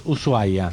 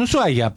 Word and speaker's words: Ushuaia: Igual son creencias, porque viste, a veces de golpe entras, Ushuaia, sí. – Que me encanta que se escriba SH, pero no se pronuncia, la H es muda.